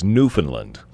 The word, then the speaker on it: Newfoundland: What is the correct radio pronunciation? – The proper radio pronunciation is Newfoundland.